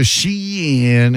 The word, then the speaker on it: Shein: That's how you say it? Shein? Like, Shein. – Yeah, and I'm not going to do the Shein these.